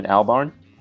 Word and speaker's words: Albarn: Damon Albarn.